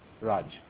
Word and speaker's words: Raj: Sure, I'm Raj. – Could you spell it? – Sure, it's R like Romeo, A Alpha, J Juliet. Raj.